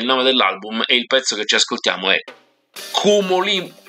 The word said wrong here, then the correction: Cumulonimbus: Questo è difficile, anche se non è giapponese, ma non ci riesco a pronunciarlo. Si chiama Cumulonimbus. Ecco, ce l'ho fatta. Cumulonimbus, lo ridico, Shigeharu Mukai.